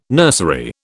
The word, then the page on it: nursery: British English